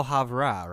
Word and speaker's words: Havre: It's like El Havre, right? Like, that's it. It's spelled H-A-V-R-E.